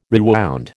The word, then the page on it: rewound: American English